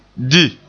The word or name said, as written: di